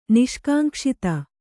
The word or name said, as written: ನಿಷ್ಕಾಂಕ್ಷಿತ